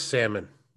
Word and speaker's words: salmon: It is salmon. – No. – Yes, salmon. The L is silent.